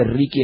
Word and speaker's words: Ricky: Y vos después le vas a explicar cómo se escribe, Ricky, R-I-C-K-Y, Pascus, P-A-S-H-K de kilo, U-S.